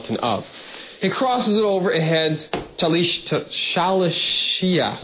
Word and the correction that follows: Shalishah: I'm going to pronounce it correctly. Shalishia. Shalishah.